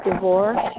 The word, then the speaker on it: DeVore: D-A-W-N. Both my phone number. My name is Dawn DeVore. D-A-W-N-D-E-V as in Victor. – What did you say? What came after D-A-W-N? – My last name, and it's DeVore. And that's spelled D-E. V as in Victor, O-R-E, one at Gmail.